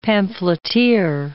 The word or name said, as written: pamphleteer